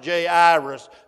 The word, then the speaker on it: Jairus: But as best I know, he got a long I in the middle of it and a long A, and his name is pronounced Jairus.